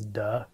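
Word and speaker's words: duh (德): unfortunately the Mandarin pronunciation is duh, which sounds kind of funny, but it's often translated as virtue, or it means something like charismatic power, charismatic virtue.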